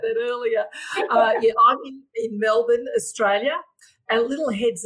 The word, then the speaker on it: Melbourne: So even though it's spelt Melbourne, we say Melbourne. So we sort of swallow about three letters there in our name.